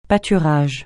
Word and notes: pâturage: francais

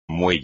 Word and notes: muelle: espagnol

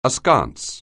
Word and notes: askance: anglais

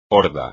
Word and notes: horda: espagnol

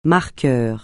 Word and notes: marqueur: francais

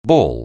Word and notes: ball: anglais

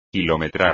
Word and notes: kilometrar: Spanish

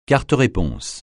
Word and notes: carte-réponse: francais

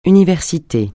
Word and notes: université: francais

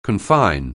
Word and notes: confine: anglais

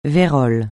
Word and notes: vérole: francais

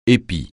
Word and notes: épi: français

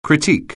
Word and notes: critique: anglais

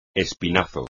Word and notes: espinazo: espagnol

